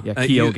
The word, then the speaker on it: Keoghan: Did I say his name right, by the way? How Kagan. – Am I supposed to say that? Kagan? – Yeah. – Keoghan.